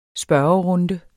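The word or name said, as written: spørgerunde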